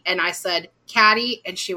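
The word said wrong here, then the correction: Cady: went, "It's Katie," and I was like, "Oh, cool."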